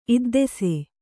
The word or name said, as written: ಇದ್ದೆಸೆ